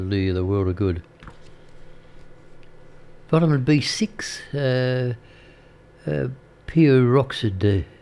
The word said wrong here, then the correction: pyridoxine: It's a bit of a pyridoxin. That's the correct pronunciation of it. Pyridoxine. Pyridoxine. Yeah, cause that's better.